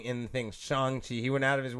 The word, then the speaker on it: Shang-Chi: It's Shang-Chi.